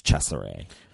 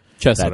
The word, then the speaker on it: Cesare: Well, when I look it up, because it's spelled C E S A R E, and then later in the film, once it's revealed that the doctor's inspired by this Caligari from Italy, I have to assume that it's Cesare. – Cesare, that's like the Italian. – Cesare. – Yeah, that's the Italian pronunciation, at least that I could find.